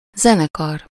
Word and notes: zenekar: /ˈzɛnɛkɒr/

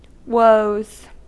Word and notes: woes: /wəʊz/

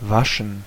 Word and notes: waschen: [ˈvaʃn̩]; [ˈvaʃən]

